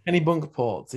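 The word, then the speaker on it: Kennebunkport: Kenny Bunkerport.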